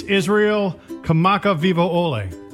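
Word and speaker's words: Israel Kamaka Vivo Ole (Israel Kamakawiwoʻole): It's Israel Kamaka Vivo Ole.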